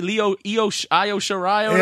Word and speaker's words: Io Shirai: Lacey, Lo Shirai, whatever you call it. Io Shirai.